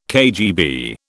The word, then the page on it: KGB: British English